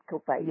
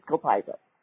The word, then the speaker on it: copaiba: Well, if you want to pronounce it correctly, it's Copaiba. – Yeah. – But if you want to do the English pronunciation, it's just Copaiba.